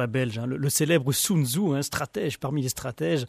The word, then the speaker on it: Sun Tzu: Sun Tzu. – Vous le prononcez extraordinairement bien, dit ça, je suis impressionné.